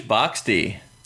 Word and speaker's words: boxty: Irish boxty. – Irish boxty. B-O-X-T-Y. Boxty. – Boxty. – Boxty. – I'm feeling a little boxty. – Boxty. That's how you pronounce it with the accent.